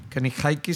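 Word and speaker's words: Kenny Hickey: Kenny Haiki si se pronuncia así, eh, Johnny Kelly, que tocan en Type of Negative y Silverton.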